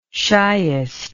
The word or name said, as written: shiest